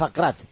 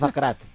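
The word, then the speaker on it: Aguerd: Aguer, ¿no? Pues según el cocinero de Lavacando, que es marroquí, Nayef Akrat. Agárrate, que hay curva, Giovanni. Nayef Akrat.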